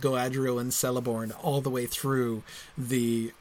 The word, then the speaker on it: Celeborn: No one's going to look at K-E-L-E-B-O-R-N and mispronounce that, right? Celeborn, clearly it's pronounced Celeborn with a K, right? When he changes it from Celeborn with a K to Celeborn with a C, the pronunciation doesn't change,